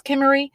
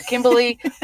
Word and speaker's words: Kimmery: My second book that I'm talking about is Queen of Hearts by Kimmery Martin, which is also a debut novel. And it is Kimmery, not Kimberly or anything like that.